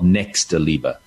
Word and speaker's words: Nächstenliebe: In Germany, it's called Nächstenliebe.